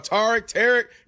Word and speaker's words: Tarik: First of all, put some respect and say his name right. It's Tarik. – Whatever. Tarik Skubal is what I call him. – That's what everybody taught. Tarik.